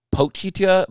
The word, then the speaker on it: Pochita: It's Pochita, but I cannot pronounce it right, so I'm calling him Pochi this whole thing.